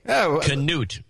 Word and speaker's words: Canute: Canute is the way it's pronounced.